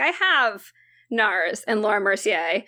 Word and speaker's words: Laura Mercier: Is it Laura Mercier? – Yes.